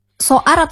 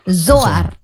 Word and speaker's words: Zoar: Kalau kita lihat dari yang tahun pertama ada tidak evaluasinya, dan mungkin apa yang membedakan Soar atau Soar nih bacanya? – Zoar.